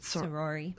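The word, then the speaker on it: saori: In just right around the corner is a sayori. I think that's how you say it. – Sorori. – Sorori. – Mm-hmm. Weaving. – Oh, that's a word that does not pronounce like it's written.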